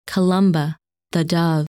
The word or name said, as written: Columba